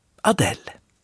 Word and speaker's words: Adele: Namnet har samma forntyska ursprung av Edelbird, men det engelska förnamnet stavas lite annorlunda och uttalas Adele.